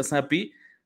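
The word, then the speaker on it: Zappe: Entró el novato Bailey Zappi, que de entrada, Andrés, ya vi los memes, o sea, porque digo que se pronuncia Zappi, se escribe Zape.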